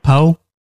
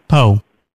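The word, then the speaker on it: Po: Poe. – Pardon? – Poe. – Spell that for me, please. – P-O.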